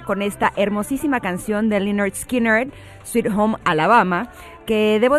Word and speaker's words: Lynyrd Skynyrd: Y él me dijo, Lynyrd Skynyrd, y ahí dije, perfecto,